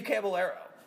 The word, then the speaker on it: Caballero: I'm going to say his name wrong. Steve Caballero. – No, that's right.